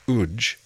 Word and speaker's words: UWJ: Spelt UWJ and pronounced UJ, the following piece was written by Colin Spears for viola and piano in 1991.